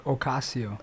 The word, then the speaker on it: Ocasio: Ocasio. – Ocasio. – It's like Casio watch with the O in front.